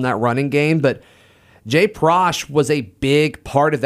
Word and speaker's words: Prosh: Actually, I think it's Prosh. I think it rhymes with squash.